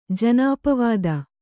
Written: ಜನಾಪವಾದ

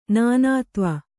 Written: ನಾನಾತ್ವ

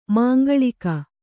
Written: ಮಾಂಗಲಿಕ

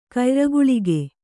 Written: ಕಯ್ರಗುಳಿಗೆ

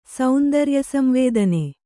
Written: ಸೌಂದರ್ಯಸಂವೇದನೆ

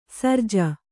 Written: ಶರಜಾ

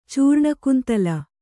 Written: ಚೂರ್ಣಕುಂತಲ

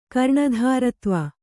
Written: ಕರ್ಣಧಾರತ್ವ

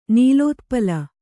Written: ನೀಲೋತ್ಪಲ

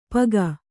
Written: ಪಗ

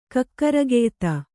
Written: ಕಕ್ಕರಗೆಯ್ತ

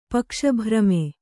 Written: ಪಕ್ಷಭ್ರಮೆ

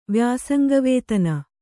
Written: ವ್ಯಾಸಂಗವೇತನ

